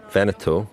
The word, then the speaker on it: Veneto: I noticed that you put the emphasis on the right syllable in Veneto there.